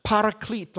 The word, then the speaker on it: paraclete: But the original word found here is advocate, and none other than this, if I'm not mistaken, is pronounced paraclete or parakletos.